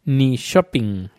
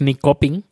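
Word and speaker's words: Nyköping: Y está, seguimos la, eh, la costa hacia el suroeste y hay una población que se llama Nishoping, N-Y-K-O con dos puntitos, Ping, P-I-N-G, Nishoping, la K no, es ni coping, es Nishoping, la K con... Em, e y, y, que la siguiente letra le sigue la O con los dos puntitos se pronuncia sho. Si no, sería co pero como tiene la O con los dos puntitos que le sigue a la K, es un sonido shh, ni shopping.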